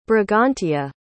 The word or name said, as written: Brigantia